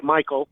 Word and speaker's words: Maycol: And it's spelled M-A-Y-C-O-L, but it kind of sounds like Michael, and then J-U-S-T-O, Justo.